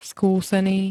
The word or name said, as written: skúsený